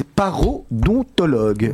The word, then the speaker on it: parodontologue: Vous êtes parodontologue.